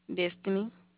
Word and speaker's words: Destiny: Destiny.